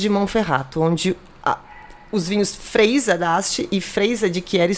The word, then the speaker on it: Freisa: É Freisa mesmo, gente. É assim que se pronuncia.